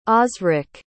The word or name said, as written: osric